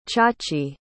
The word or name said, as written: Chachi